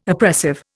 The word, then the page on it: oppressive: Indian